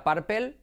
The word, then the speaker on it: purple: Si sabes decir paper bien, repite conmigo, paper, tienes la primera sílaba de purple. Repite conmigo, paper. Por lo tanto, purple. ¿Tú pensabas que se decía purple?